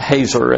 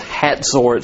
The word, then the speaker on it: Hazor: And it's not Hazor as it looks like, but it's Hazor. It's like it's pronounced like H-A-T-Z-O-R, Hazor.